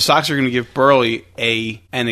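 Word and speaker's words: Buehrle: Dude, I read on SportsKey that the Sox are going to give Burley That's right.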